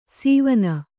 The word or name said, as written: ಸೀವನ